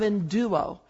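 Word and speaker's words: enduo (ἐνδύω): Evato. Evato is what, how do you say it? – It's enduo. – Enduo. Thank you.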